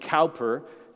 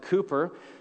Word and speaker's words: Cowper: William Cowper, and by the way, Americans, we always pronounce it wrong. We want to pronounce it Cowper, C O W P E R. The Brits pronounce it Cooper.